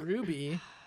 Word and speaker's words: RWBY: I think it's pronounced Ruby. – I think it's pronounced Ruby. – Okay, from Ruby, and Amber's favorite.